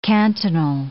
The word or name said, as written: cantonal